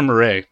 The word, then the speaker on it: Miret: Roger Miret. It's like Rogerio Murray.